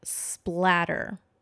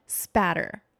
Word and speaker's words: spatter: So one of our listeners pointed out that it's not blood splatter. It's blood spatter. There's no L in it.